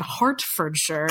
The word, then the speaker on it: Hertfordshire: And it is Derbyshire and Hertfordshire, though they're E's.